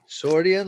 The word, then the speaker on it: Sordian: Sordian like accordion.